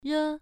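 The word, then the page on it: re: tone 1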